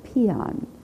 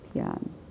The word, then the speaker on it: Paean: Peon. Peon. I always have to write it out. Peon. Peon. The word P-A-E-A-N, isn't it? And sometimes, it's spelled P-A-E-I-O-N. I think there's an I-O-N in the original. Peon.